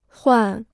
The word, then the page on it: 换: huàn